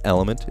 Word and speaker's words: LMNT: It's pronounced element, it's spelled LMNT, including 8 packets of elements, 2 citrus, 2 raspberry, 2 orange, and 2 raw unflavored.